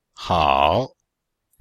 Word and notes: hao: hao3

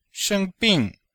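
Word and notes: sheng1bing4: tone 1; tone 4

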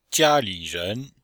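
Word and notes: jia1li3ren2: tone 1; tone 3; tone 2

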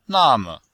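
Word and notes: name: na4me5

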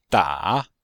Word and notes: da: da3

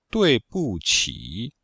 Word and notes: dui4bu4qi3: pinyin

